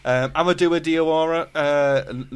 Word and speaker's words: Amadou Diawara: Amadou Diawara, Napoli midfielder, €35 million.